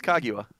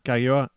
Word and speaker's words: Kaguya: Cause you got it right the first time that there, though. It's Kaguya. – Kaguya, yeah.